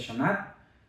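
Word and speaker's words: Xanat: Shanad significa flor de vainilla en Totonaca. Es, este, se pronuncia Shanat,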